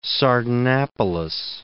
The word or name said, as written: Sardanapallus